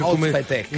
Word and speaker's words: Özpetek: Comunque, ti posso dire una cosa: tu prima, hai fatto tutto lo sforzo per pronunciare Özpetek. – Özpetek. – Invece, Ferzan... – No, l'ha detto bene.